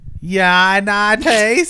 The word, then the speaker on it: Yaitanes: Y-N-A-C-A? – Y-A-I-T-A-N-E-S. – Yanates.